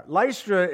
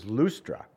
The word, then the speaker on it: Lystra: Lystra, in reality, we read it as Lystra. The real pronunciation is Lustra.